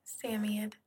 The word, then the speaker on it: Samoyed: So, um, how do you pronounce it? It's Samoyed? – Samiad. – Samiad.